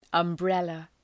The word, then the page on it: umbrella: ʌm’brelə